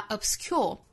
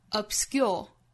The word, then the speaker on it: obscure: 那 s 后面的清辅音不送气，不发 obscure 而是发成 obscure，从 k 的音变成类似于 g 的发音 obsc, obscure, obscure。